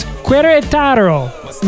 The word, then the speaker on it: Querétaro: It's in, I'm going to butcher this, Querétaro, Mexico. – That was a pretty good rendition right there. – In Querétaro, Mexico, if you Google them, go on Google Maps.